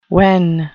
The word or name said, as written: wen